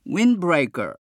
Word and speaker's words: windbreaker: そして windbreaker。日本語ではウィンドブレーカーですが、英語ではウィンブレーカー。